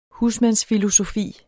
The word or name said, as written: husmands-